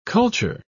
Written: culture

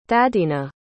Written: thadina

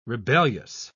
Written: rebellious

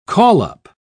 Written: call-up

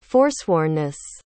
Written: forswornness

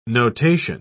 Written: notation

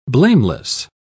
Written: blameless

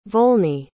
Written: volney

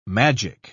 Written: magic

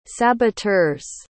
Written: saboteurs

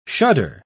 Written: shudder

